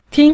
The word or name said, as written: ting5